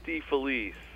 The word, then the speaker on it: DeFelice: Well, I say it DeFelice. – That's what I said. – And the reason I say it that way is because my grandfather, who came from Italy, said it that way.